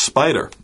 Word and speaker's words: SPDR: So in some ways, actually, SPDR is a very commonly pronounced spider.